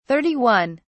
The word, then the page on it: 31: British English